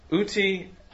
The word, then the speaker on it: uti: For Augustine, there are 2 modes of love, uti and frui. Peter, did I pronounce those right? – Yeah, that was good.